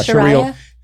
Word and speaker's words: Sharia: Or the Sharia? – Yeah, Sharia.